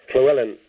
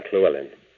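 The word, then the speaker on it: Llewellyn: And I was saying, you pronounced it wrong. The Wellen bit's fine, but the Hle is a crime. Clewellyn is a Welsh name. And in Wales, you pronounce la, Cle, if there's a double L in it.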